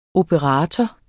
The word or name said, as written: operator